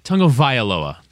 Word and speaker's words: Tagovailoa: I want to say Tagovailoa. Am I saying it correctly? Because I'm scared of it. – I think you got it. – Needs a little I. Tagovailoa.